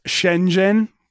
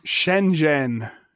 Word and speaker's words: Shenzhen: So, it's pronounced Shenzhen, according to a video of how to pronounce Shenzhen in a Chinese way. – Shenzhen.